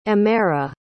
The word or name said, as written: emera